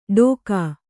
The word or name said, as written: ಢೋಕಾ